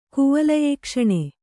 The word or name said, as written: ಕುವಲಯೇಕ್ಷಣೆ